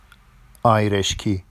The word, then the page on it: ɑjreʃci: Vafsi